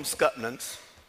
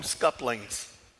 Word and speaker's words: scuppernong: Now, I don't know how you're supposed to pronounce that word. It is spelled S-C-U-P-P-E-R-N-O-N-G. So it should be pronounced Scuppernong. We called them Scutnans. Like you had muscadines, you had Scuppernongs. Get to Middle Georgia, I find out some of the folks in Middle Georgia call them scuplings.